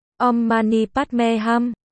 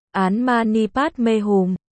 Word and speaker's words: Om Mani Padme Hum: Theo tiếng Phạn là Om Mani Padme Hum, có vài cách đọc theo tiếng Việt là Án Mani Padme Hum, Án Mani Bát Di Hồng, Án Mani Pết Nạp Minh Hồng.